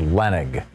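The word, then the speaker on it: Leonnig: Yeah, I learned after having her on as a guest or her co-author that it's actually pronounced Leonig, but it's spelled L-E-O-N-I-G, so I get what you're saying, Bob.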